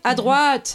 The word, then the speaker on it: à droite: How do we say that again? – To the right, à droite. – À droite.